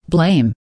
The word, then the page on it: blame: American English